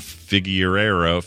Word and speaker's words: Figueiro: Figueiro. How would you say that? – Figueiro. Figueiro. – All right. Yes. That was close. – Although there's an accent on the O. Figueiro.